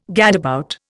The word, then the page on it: gadabout: Indian